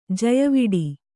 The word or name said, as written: ಜಯವಿಡಿ